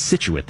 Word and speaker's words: Scituate: Gil, the only one you were incorrect on was Scituate. Apparently it's pronounced Scituate, that one town. Emphasis on the sit.